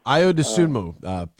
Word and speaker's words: Ayo Dosunmu: Ayo Dosunmu, potentially the Big Ten player of the year.